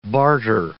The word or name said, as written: barter